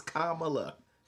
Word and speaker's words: Kamala: I'm telling you, her name is Kamala. She's already said that. That's how you pronounce her name. You keep choosing to say the opposite because Donald Trump did the same thing. And I wonder why. – Kamala. It's like a Millie.